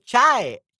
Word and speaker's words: chai: Oh, is you already pronounced it te, te kai. Oh, chai, chai. So chai.